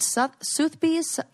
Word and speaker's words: Sotheby's: Is it Sotheby's? – Uh-huh.